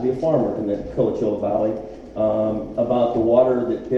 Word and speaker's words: Coachella: Interesting thing, we got to Coachella Valley, am I saying that right? – Coachella, and we did a tour of their water.